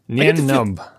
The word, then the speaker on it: Nien Nunb: Nom Nubbin. Empire co-pilot. – Nien Nub. – That's it. – N-I-E-N-N-U-N-B. – N-I-E-N.